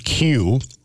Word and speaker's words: queue: Did you know the word Q, as in Q-U-E-U-E, is actually pronounced the same way when the first four letters are removed?